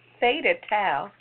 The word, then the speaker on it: Theta Tau: Caucasians with a fraternity called, how you pronounce it? Taka? – Theta Tau. – Theta Tau.